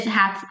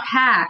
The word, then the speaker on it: Ha: I don't really pronounce it that way when I'm here because it has a little bit tone and stuff. My name is actually not Ha. It's like Ha, you know? – Yeah, I know we've gone through that.